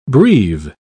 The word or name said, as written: breve